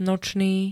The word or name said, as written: nočný